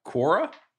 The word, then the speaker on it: Quora: How do you say that? – Quiro. – Q-U-A-R. – Quora. – Quora. – That's how I would say it.